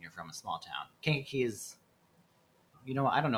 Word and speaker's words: Kankakee: So you grew up in just south of Chicago called Kankakee.